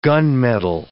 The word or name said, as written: gunmetal